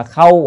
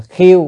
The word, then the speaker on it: khâu: Quốc gia nào rồi, thành thị nào rồi, chữ khâu, chữ này đọc là khâu hoặc là khiêu cũng được, tùy kheo á, tùy khiêu vậy á, thì có mấy âm nữa.